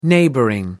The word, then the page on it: neighboring: British English